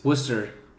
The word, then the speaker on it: Worcester: Worcester. Not to be confused with Worcester, which is how it's spelled.